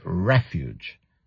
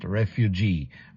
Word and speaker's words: refuge: Yes, Virginia, attention to the pronunciation. It's refuge. Don't confuse it. It's not refugee.